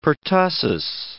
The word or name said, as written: pertussis